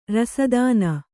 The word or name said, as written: ರಸದಾನ